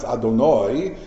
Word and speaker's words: Adonai: If you're saying the Shem Hashem, I'm just saying now because we're teaching, the Shem Hashem you pronounce Adonai, but Adonai can mean my master.